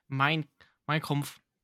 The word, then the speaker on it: Mein Kampf: Oh, you were saying Mein Kampf. I thought you were saying Minecraft or something. I was so confused. Please work on your pronunciation.